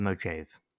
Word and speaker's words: Mojave: And there's also in the Mojave. – It's pronounced the Mojave.